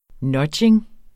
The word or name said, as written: nudging